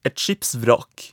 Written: et skipsvrak

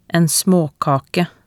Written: en smaakake

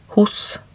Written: hos

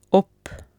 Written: opp

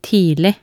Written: tidlig

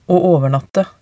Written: å overnatte

